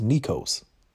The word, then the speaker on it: Nekos: Like my aunts and my granny, like they don't they don't pronounce my real name correct. They call me Nicole. If you've ever been around them, then you know. They'll say with the quickness, Nicole. And I remember one of my aunts asked me, she was like, What is your real name? I was like, It's Nekos. And they was like, Oh, we've been just saying it wrong.